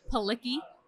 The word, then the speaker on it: Palikij: It's Palikij. – Palikij? – There's a silent J in there, so it just throws everyone off.